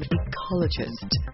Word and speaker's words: Echologist: with a release from Ecologist. I love it. It's spelled E-C-H-O-L-O-G-I-S-T, who returns to Night Grooves for a second time, bringing more of his super dub sonic sounds to this release.